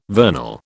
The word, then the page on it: vernal: British English